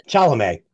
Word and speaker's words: Chalamet: played by Timothy Chalamet. Or Chalamet, I don't know how you pronounce it. – Chalamet. – Chalamet? All right. – Sure. – Let's go with that.